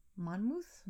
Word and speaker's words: Monmouth: M-O-N-M-O-U-T-H. Monmouth? – Monmouth.